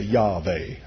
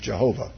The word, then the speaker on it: Jehovah: We pronounce it. Some say Yahweh. I stick with Jehovah.